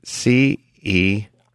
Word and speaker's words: CEREC: CEREC. And it rhymes with Eric.